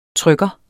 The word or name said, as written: trykker